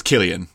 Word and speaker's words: Cillian: it's Cillian, but yes, Cillian Murphy. – Cillian. Sorry, I'm not Irish. Sorry, I apologise. I don't know how it's pronounced.